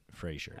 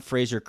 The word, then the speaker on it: Fraser: it is clearly F R A S E R and yeah, pronounced yeah. Fraser. Right. Not not Frasier Crane from from the great sitcom Frasier.